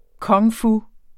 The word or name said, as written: kung-fu